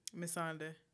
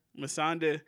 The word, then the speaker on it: Missandei: Missandei. – Missandei.